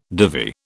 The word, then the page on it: divi: British English